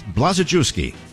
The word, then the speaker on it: Blazejewski: Now, on day one, let's get his name right. It's Blazejewski. Four syllables, a lot of letters.